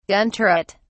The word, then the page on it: gun turret: British English